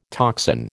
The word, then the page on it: toxin: American English